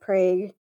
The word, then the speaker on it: Prague: We also have Prague, Oklahoma, which is actually, you know, it's spelled like Prague, like in the Czech Republic. It is actually a town that was largely settled by Czech immigrants, but we pronounce it Prague.